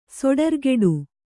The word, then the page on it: ಸೊಡರ್ಗೆಡು: Kannada